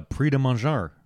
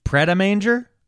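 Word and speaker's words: Pret A Manger: Damn, dude, he blew up faster than like a Prix de Manger. – Yeah. So I'm going to ignore that. – What? – And to how I pronounced it? – Pret-a-manger?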